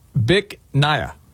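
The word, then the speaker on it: Bich Nga: you pronounce it Bic Naya. – Bic Naya? – Yes, but it's spelled B-I-C-H. One word. Yeah. Second word, N-G-A.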